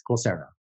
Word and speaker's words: Coursera: Now, you are the co-founder of a group called Coursera. Is that how you pronounce it? – Yes, Coursera.